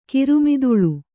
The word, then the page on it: ಕಿರುಮಿದುಳು: kiru miduḷu